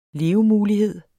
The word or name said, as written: levemulighed